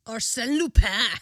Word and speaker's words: Arsène Lupin: Arsène Lupin.